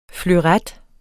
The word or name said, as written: fleuret